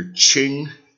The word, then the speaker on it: Qing: Qing, Q-I-N-G, and we have Ming, M-I-N-G. Rhymes with ring, R-A-N-G, rhymes with sing, S-I-N-G, rhymes with ding, D-I-N-G, rhymes with fling, F-L-I-N-G. So, the old spelling for the Q-I-N-G was C-H-I-N-G, so that's Qing, and then the mainland Chinese authorities changed their spelling. So, Q-I-N-G, Qing, Qing Dynasty, and M-I-N-G, Ming, Ming Dynasty.